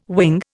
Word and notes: wing: Indian

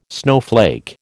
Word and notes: snowflake: American English

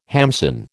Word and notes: hamsin: American English

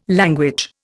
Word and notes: language: Indian

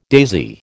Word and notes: daisy: American English

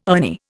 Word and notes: Ernie: Indian

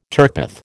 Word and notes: turpeth: American English

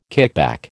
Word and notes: kickback: American English

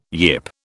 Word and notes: yip: British English

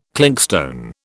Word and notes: clinkstone: British English

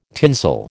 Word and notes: tinsel: American English

